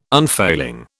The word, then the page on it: unfailing: British English